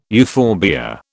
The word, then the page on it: euphorbia: British English